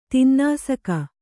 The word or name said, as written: ತಿನ್ನಾಸಕ